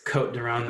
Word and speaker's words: Côtes du Rhône: Sometimes I can find like Côtes du Rhône for, or I think it's Côte du Rhône, the S is silent, but I'm American, dang it.